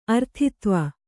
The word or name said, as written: ಅರ್ಥಿತ್ವ